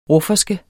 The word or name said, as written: RUFFERSKE